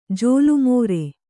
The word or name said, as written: ಜೋಲುಮೋರೆ